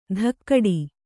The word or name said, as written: ಧಕ್ಕಡಿ